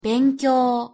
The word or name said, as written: benkyo